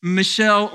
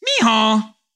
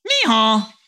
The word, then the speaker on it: Michal: Now, first, her name here isn't pronounced Michelle or Michael. Anytime you see this word in the Bible, the best way I can think of to remember how to pronounce it is, you know that sound that a donkey makes? Michal, Michal. That's her name.